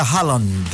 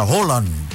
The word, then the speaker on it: Haaland: No diga Haaland, diga Holland.